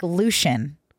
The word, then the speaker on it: Lucien: L-U-C-I-E-N. – Lucien. – I mean, that's Connor. Thought the same thing. – Is it Lucien? – It's like Lucian.